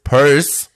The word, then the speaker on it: Peirce: Und das beispielsweise drückt sich insbesondere dadurch aus, dass er 1872 einen Club zusammen mit seinem Freund Charles Saunders Peirce gründete. Ne? – Was den tatsächlich so ausgesprochen wird.